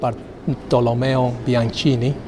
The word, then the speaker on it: Bartolomeo Bianchini: This is, and I'm going to go for the correct pronunciation here, I'm not going to mess about with this one. This is called Bartolomeo Bianchini.